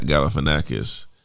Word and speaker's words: Galifianakis: Zach Galifianakis. If you know who that is. – Yo, that's crazy. You actually pronounced it.